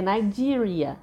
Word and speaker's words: Nigeria: Então, por exemplo, a palavra Nigéria em inglês, se você não conhece o som do i em certas situações, você pode acabar errando, falando errado. E é Nigeria.